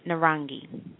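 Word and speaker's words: Narangi (नारंगी): And orange is pronounced Narani. Narani. And that is spelled N as in Nathan, A-R-A-N-G-I, Narangi.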